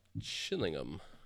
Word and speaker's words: Chillingham: Chillingham. You don't say the H in England. Chillingham. Did you know that? – It's. You don't say the H? – Chillingham. Not Chillingham.